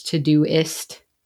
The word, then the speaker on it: Todoist: Instead of to do list, it's Todoist.